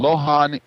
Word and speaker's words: luohan: One of the ways it's spelled is L U O H A N, but pronounced Lohan, it also has a soluble fiber inside the fruit, which isn't sugar.